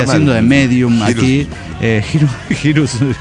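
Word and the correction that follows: Hiren's Boot: No, se llama Irens Bot. Se escribe H-I-R-E-N-S Bot, B-O-O-T.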